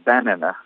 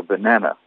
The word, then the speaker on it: banana: Par exemple, le mot banane, banana, vous ne pouvez pas dire banana, il faut dire banana, avec l'accent au milieu sur la deuxième syllabe.